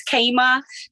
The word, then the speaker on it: Chama: You will never, never be able to find it because it's spelled not even the way it sounds. So Elizabeth, I don't know whether there's transcripts or links with your podcast, but you can find me at C-H-A-M-A. So it's spelled like Chama. If you look up my first name and my last name, W-O-Y-D-A-K, you'll find me on Instagram and Facebook also.